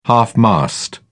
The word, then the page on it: half-mast: British English